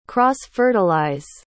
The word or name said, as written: cross-fertilize